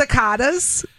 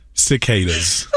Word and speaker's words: cicadas: Is it the cicadas? – Oh, Lord. No. No. It's pronounced cicadas.